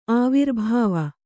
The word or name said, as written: ಆವಿರ್ಭಾವ